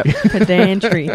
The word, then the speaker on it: pedantry: You've got the emphasis on the wrong syllable. – Yeah, pedantry.